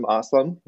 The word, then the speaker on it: Aslam: Today, I have a very special guest, Kasim Aslam, rhymes with awesome,